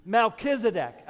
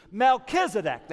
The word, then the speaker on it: Melchizedek: I want you to say that name on three. One, two, three. Melchizedek. Again, one, two, three. Melchizedek. That's how we pronounce his name.